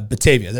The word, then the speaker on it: Batavia: Am I going to say this right? Batavia? Batavia. – Yeah, Batavia. There you go.